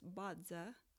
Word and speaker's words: Bazi: Now, if you're not familiar with Bodza, it's spelled B-A-Z-I, and it's pronounced Bodza, like a D sound.